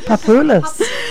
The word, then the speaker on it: Papoulis: I've never said his name right. I think it's Papoulis. – Papoulis. – And it's arranged by Francisco J. Nunez. – Okay. A Greek, obviously, Papoulis.